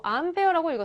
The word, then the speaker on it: 암페어: 우리 전류의 단위로는 A라고 쓰고 암페어라고 읽었습니다.